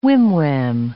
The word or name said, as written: whim-wham